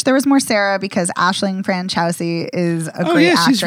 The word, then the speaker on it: Aisling: I wish there had been more Sarah, just because I like Aisling What's-Her-Face.